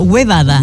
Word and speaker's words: huevada: Hoy día, por ejemplo, iban a hacer una huevada. – Yes. Está muy bien pronunciado, huevada.